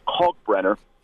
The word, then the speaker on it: Kalkbrenner: And, I mean, you got the big man, Kalkbrenner, who, you know, Charles Barkley can't pronounce his name correctly, but it is Kalkbrenner.